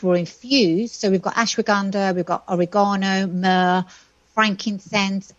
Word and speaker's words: ashwagandha: Frankincense, myrrh, ashwagandha. Yeah. Is that how you pronounce that? Ashwagandha? – Ashwagandha. – So ashwagandha.